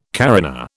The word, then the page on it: carina: British English